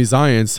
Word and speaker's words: Zients: Jeff Zients. – Zients. – Z-I-E-N-T-S. – I saw Shlomi Zients had reported that he's not he doesn't think they're related.